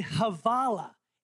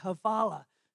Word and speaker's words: hvala: You say Havala. H-V-A-L-A. Havala. Thank you in Bosnian.